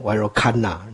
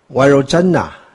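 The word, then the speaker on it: Virochana: 这个 CH 哈，本来应该是 C，但是如果光是写 C 哦，多半洋人都会念成 Virojana，那就不对了啊。所以我就特地把它英文化。啊，这个 Virojana，那 Virojana 是毗卢遮那啊，毗卢遮那是翻 Virojana 的音，所以是音译。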